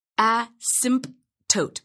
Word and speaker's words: asymptote: Some people say asymptote, but I never pronounce the P.